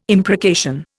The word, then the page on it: imprecation: Indian